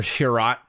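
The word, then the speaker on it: Chiarot: So the the the trade you're referencing is Ben Chiro or Chirot, as the Americans pronounce it.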